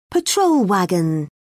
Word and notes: patrol wagon: British English

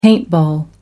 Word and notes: paintball: American English

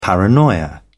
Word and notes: paranoia: British English

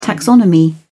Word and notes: taxonomy: British English

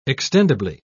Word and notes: extendible: British English

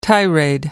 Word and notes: tirade: American English

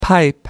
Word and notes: pipe up: American English

